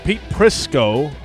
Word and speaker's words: Pete Prisco: Oh, I said your name wrong. Pete Prisco.